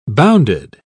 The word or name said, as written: bounded